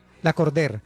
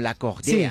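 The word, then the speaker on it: Lacordaire: La Cordère. – Pero como en francés, como La Cordère. Sí. Una cosa así. – Es que es un nombre francés. Sí, se escribe La Cordaire, pero se pronuncia La Cordère.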